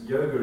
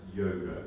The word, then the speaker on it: yoga: They make a distinction actually, because yoga is a Sanskrit word, and so it's pronounced yoga. But when Sanskrit words are used in vernacular languages in India, they drop the final A. So in Hindi, yoga is not yoga, it's yog.